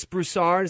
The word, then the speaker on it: Broussard: Let me just play a quick clip from a radio program where Chris Broussard, is that how I say his name? – Yeah.